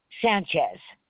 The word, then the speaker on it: Sanchez: Sanchez. – All right. One second here. All right, and that's spelled S-A-N-C-H-E-Z? – Yes.